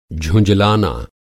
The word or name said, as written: झुँझलाना